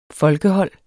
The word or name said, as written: folkehold